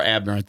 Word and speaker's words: obdurate: O-B-D-U-R-A-T-E. It's a fun one. – And it's pronounced abnorrant? – I believe it is. – O-B. – O-B. – D-U-R-A-T-E. – Abnorrant? Stubbornly refusing to change one's opinion or course of action. – Yeah. – There it is. – And I think he's got the pronunciation right, too.